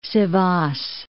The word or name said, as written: Sivas